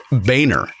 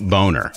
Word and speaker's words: Boehner: Okay, so the question was a little misleading, and we pride ourselves on accuracy, so it's actually pronounced Boehner, not Boner.